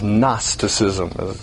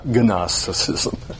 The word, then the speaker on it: Gnosticism: The G is silent. So the way you pronounce that is Gnosticism, not Gnosticism.